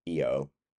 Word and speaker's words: Io: Not yo as in yo yo. It's IO. So some people would pronounce it EO, but it was kind of like it's kind of just saying I in Italian.